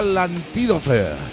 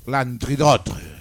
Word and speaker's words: L'Antidote: L'Antidote. – Que yo estudié francés cuando era pequeña. Entonces, normalmente la última no se pronuncia como...